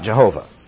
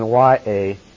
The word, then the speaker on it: Yahweh: One thing is certain. Whatever if the pronunciation is, it is not Jehovah. That we can be certain of. It is most likely that you would put in YAHWEH, and thus you have Yahweh.